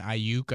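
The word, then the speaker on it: Aiyuk: Arizona State, Brandon Ayuk, I believe is the pronunciation on that.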